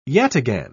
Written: yataghan

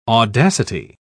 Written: audacity

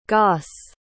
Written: goss

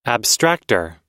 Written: abstractor

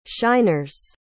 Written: shiners

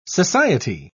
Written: society

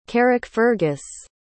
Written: carrickfergus

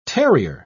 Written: terrier

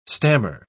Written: stammer

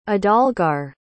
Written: adalgar

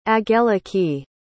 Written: angeliki

